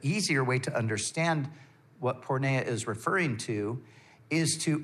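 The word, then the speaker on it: porneia (πορνεία): So sexual immorality is the translation of a Greek word that is pronounced porneia.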